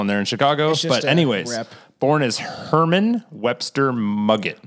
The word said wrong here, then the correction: Mudgett: Muggett. It's Mudgett. It's Mudgett. Pronounced Mudgett.